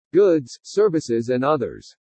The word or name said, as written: GOODS SERVICES AND OTHERS